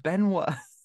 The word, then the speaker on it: Benoit: Benoit.